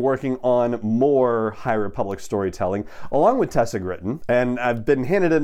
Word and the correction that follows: Gratton: It's Tessa Gratton, not Gritton. My apologies on the pronunciation. I was putting a short I instead of a short A in there for some reason. So Tessa Gratton there you go.